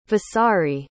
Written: vasari

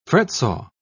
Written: fretsaw

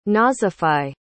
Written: nazify

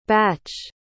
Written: bache

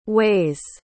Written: waise

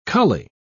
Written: cully

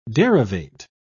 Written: derivate

